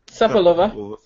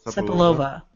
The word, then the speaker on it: Cepelova: C-E-P-E-L-O-V-A. How do you say that? – Sepelova. – Sepelova.